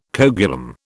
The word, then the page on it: coagulum: British English